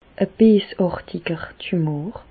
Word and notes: e beesàrtiger Tumor: Bas-Rhin; Reichshoffen

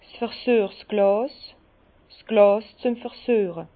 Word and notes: 's versüechsglàs, Glàs züem Versüeche: Bas-Rhin; Herrlisheim

